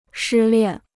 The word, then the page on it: 失恋: shī liàn